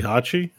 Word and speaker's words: Heihachi: Heihachi? – No.